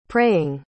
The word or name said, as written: praying